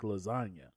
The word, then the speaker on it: lasagna: And in there, he said, real G's move in silence like lasagna. And people are like, what? The G in lasagna The G in lasagna is silent.